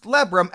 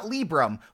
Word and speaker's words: Libram: And then I also learned today that it is pronounced Lebrum and not Librum, which is how I've been pronouncing it since 2007.